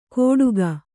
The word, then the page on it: ಕೋಡುಗ: Kannada